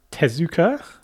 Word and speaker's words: Tezuka: Then you got Tezuka.